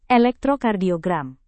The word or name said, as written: elektrokardiogram